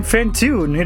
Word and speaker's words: Fantoon: I am Fantoon. It rhymes with cartoon.